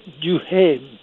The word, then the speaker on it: Duhaime: The Conservative Party just didn't exist provincially, but along came a very strange character called Éric Duhaime. We don't pronounce the H in French, but it is in the name.